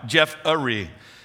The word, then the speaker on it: Jeffery: Like you would think that my name is spelled J-E-F-F-R-E-Y, right? Nope. Thank you, Mom and Dad. J-E-F-F-E-R-Y, Jeff-a-ry.